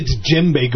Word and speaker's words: djembe: Actually, one of my favorites was there was a bumper sticker that said, you know, djembe, you know that? D-J-E-M-B-I, I think is how you say it. Djembe, it's an African. But DJ, it's that sound, not a normal English sound.